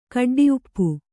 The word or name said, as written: ಕಡ್ಡಿಉಪ್ಪು